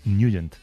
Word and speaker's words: Nugent: Hombre, siempre lo hemos llamado en el barrio Ted Nugent o Ted Nugent. – Claro, ¿verdad? Yo era de Ted Nugent. – Pero la pronunciación correcta es Nugent.